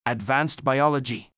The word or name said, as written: Advanced Biology